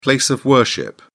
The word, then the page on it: place of worship: British English